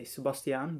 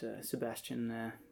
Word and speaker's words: Sebastiaan: So it's a Dutch name, so you'd say Sebastiaan, but Sebastiaan is just fine, or whatever kind of pronunciation you want to use.